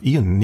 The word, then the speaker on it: Ian: Äh, ich glaube Ian, nicht Ian ausgesprochen, Ian McKellen.